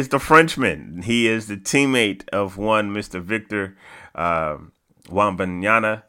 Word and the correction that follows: Wembanyama: I screwed up his name too. Hold on. Let me go back and say his name the correct way because Victor Wembanyama. Yeah, Wembanyama. Yeah.